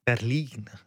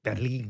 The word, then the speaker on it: Berlin: Maar in het Duits, Berlijn is toch gewoon Berlin? – Berlin, ja. Met nadruk op de I. Berlin.